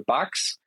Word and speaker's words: Bux: Ähm, und zwar hat das äh, Amsterdamer Unternehmen Bugs, das schreibt sich B-U-X, ich schätze mal, das spricht sich Bugs aus.